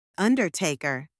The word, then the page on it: undertaker: anglais